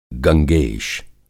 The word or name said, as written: गंगेश